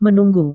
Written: menunggu

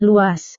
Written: luas

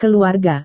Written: keluarga

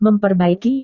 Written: memperbaiki

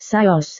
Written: saus

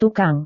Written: tukang

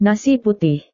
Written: nasi putih

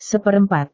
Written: seperempat

